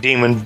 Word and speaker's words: daemon: And so therefore, daemon became... Well, it, you know, I guess they pronounce it demon, but that became evil spirit is what that became.